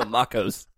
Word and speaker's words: macOS: Macaws Catalina. – Yeah, Macaws. No, Macos.